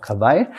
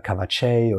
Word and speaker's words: Kawaii: Also der Kawaii schreibt man mit J hinten. Ah, passt auch geil. Dann wussten wir, okay, das einzigste Problem oder Herausforderung ist so die Aussprache, weil viele sagen Kawatschei oder Kawatsch.